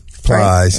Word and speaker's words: Plies: Ply says, what's his name? – Plies, right? – Yeah.